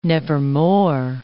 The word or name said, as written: nevermore